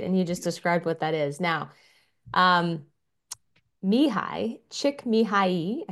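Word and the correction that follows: Csikszentmihalyi: I think is how you say his name. Chick Sent Mihai, this is how you say his name.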